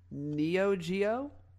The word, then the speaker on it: Neo Geo: So Soulja Boy claims that the console is capable of running PlayStation, Neo Geo, if I'm saying that right.